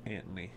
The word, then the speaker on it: Anthony: Is it Anthony or Antony? – Well, the way they pronounced it back then was Antony.